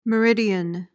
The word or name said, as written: meridian